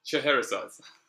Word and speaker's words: Chehrehsaz: Chehrehsaz.